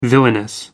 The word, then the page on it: villainous: American English